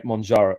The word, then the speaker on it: Mounjaro: I think that's how you say it, Mounjaro.